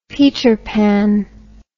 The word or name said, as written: peter pan